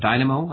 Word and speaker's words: Dynamo: And then, of course, the rise of Dynamo, I believe, is that the way I pronounce Absolutely. – It? – Absolutely, yep.